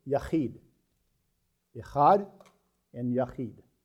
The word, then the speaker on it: echad: The first one is the word echad, E-C-H-A-D, pronounced echad.